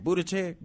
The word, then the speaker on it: Buttigieg: You know, so Buttigieg, how you say his name? Buttigieg. – Yeah.